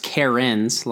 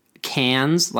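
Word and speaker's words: cairn: But I've heard it pronounced Cairns, like a female name. I've heard it pronounced. Cairns, like you'd pronounce the Australian city name. – Really? – Cairns, if you are British, saying that word.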